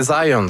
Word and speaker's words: Zajonc: Non, c'est Zajonc. – Ouais, OK. – Ouais, j'ai-tu pas dit Zajonc? – Vous avez dit plus Zajonc, mais. – J'ai prononcé un petit peu à l'anglais. J'ai prononcé mon polonais à l'anglais.